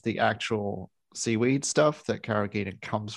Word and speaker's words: carrageenan: I think, I've been doing a bit of digging on carrageenan. It is a hard G. I found that out at least.